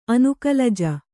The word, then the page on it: ಅನುಕಲಜ: kannada